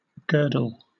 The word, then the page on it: girdle: British English